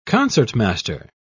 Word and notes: concertmaster: British English

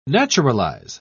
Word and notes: naturalise: British English

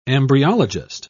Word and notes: embryologist: British English

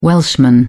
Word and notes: Welshman: British English

